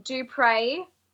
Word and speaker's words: Dupriez: So it's pronounced Dupriez. But it's spelled Dupriez. – Ez. – Yeah, it's got a silent Z. Silent Z.